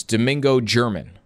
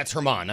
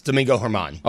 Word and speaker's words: Germán: And it says Domingo German. – That's how it's spelled, yeah. – Yeah. You were saying Germán before. – Yeah, it's Germán. I'm sorry. – Germán? – Yeah, it's Domingo Germán.